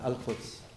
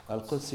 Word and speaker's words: Al-Quds: And how do you pronounce Al-Quds? Al-Quds University.